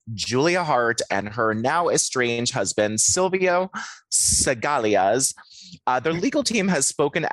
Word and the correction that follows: Scaglia: How do I say it? – I think it's just Scaglia. – Scaglia. That looks exactly what it is.